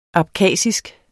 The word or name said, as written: abkhasisk